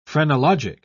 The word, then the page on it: phrenologic: British English